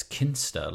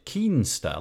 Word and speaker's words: Kinsta: Um, so right away, you've already worked out that um, I've said the name of the company wrong because the way I say it is Kinster, like K-I-N, Kinster, but you you're pronouncing it Keenster, like K-E-E-N-S-T-A. Why is that? Is there a is there a kind of like a, an origin of the company which goes to a different part of the world which pronounces it differently?